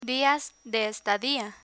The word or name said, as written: dias de estadia